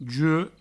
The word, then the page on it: juec: jue; Provençal